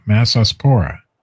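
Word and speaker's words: Massospora: So the fungus is called Mesosapora. For real. – I'm sure that's exactly how it's pronounced. – It is. M-A-S-S, Mass, O-S, O-S, Pora, Mesosapora.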